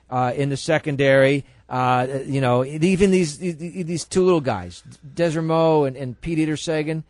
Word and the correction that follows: DeSormo: Desermo, whatever his name DeSormo. – Is. – DeSormo.